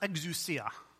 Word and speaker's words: exousia: The first one is a Greek word called, it's pronounced exousia, and it means authority.